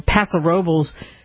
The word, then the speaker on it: Paso Robles: and in Paso Robles, which is how you pronounce it.